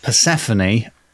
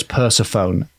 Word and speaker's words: Persephone: It was Persephone. To annoy pedants, I pronounced the name Persephone as Persephone, and I thought myself a little rascal.